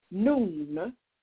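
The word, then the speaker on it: noon (نون): In Arabic, one of the meanings for the letter Noon, pronounced noon, is seed. In Arabic, one of the meanings for the letter noon, pronounced noon, N-O-N, is seed, S-E-E-D.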